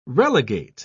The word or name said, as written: relegate